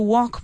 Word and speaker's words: walk: I know there's an L. It's walk. It's spelled walk. But we don't say that L.